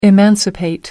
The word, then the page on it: emancipate: American English